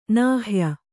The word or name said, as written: ನಾಹ್ಯ